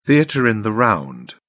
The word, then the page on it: theatre in the round: British English